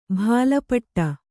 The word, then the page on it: ಭಾಲಪಟ್ಟ: bhāla paṭṭa